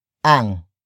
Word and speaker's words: ang: The next word is ang, which is spelled as A-N-G.